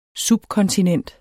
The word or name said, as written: subkontinent